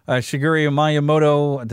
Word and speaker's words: Shigeru Miyamoto: Shigeru Miyamoto. Did I say his name right? I don't know. – That sounds right.